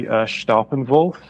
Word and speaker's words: Staeppanwulf: The correct pronunciation would be Staeppanwulf, because it's derived from an old English prefix, but anybody getting it even remotely right is good enough for me, so don't worry about it.